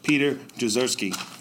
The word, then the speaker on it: Jezierski: Staff Sergeant Taylor Entrican, the United States Marine Corps, and Staff Sergeant Peter Jezierski.